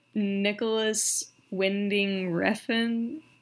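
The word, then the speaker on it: Nicolas Winding Refn: but Nicholas Winding Refn? – You got it.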